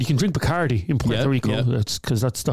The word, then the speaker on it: Puerto Rico: Why are you saying it like that? Puerto Rico. – That's the way the locals say it. Puerto Rico.